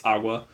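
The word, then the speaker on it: Aqours: It's spelled like Aquars, but I'm pretty sure it's Agua.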